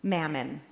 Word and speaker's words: mammon: Older translations of this text, and maybe this is a familiar phrase for some of you, you cannot serve both God, and I know I'm going to be corrected if I say this word wrong, mammon. Did I say it right, Jane? Okay, good.